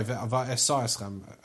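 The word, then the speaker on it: va'asa eschem (ועשה אתכם): Then the second idea is va'asi eschem ze yom shebo Yisrael laramses. Sorry, va'asa eschem. I mispronounced it.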